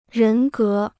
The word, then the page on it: 人格: rén gé